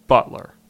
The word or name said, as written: Butler